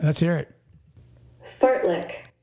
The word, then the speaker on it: fartlek: Fartlick. Fartlick. Well, like, F A R T L E K. – But it's pronounced fartlick.